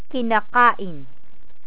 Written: kinakain

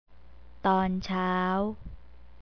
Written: tonchao

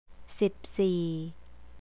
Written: sipsee

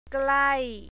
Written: klai

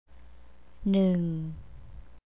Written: neung

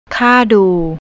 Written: kardu

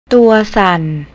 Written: touysan

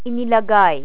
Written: inilagay

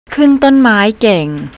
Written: kuntonmai

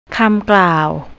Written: kamklow

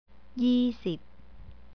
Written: yeesip

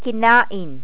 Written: kinain

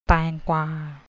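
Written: แตงกวา

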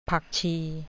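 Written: ผักชี

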